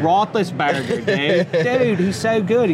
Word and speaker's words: Roethlisberger: Ben Roethlisberger.